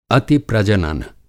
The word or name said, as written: अतिप्रजनन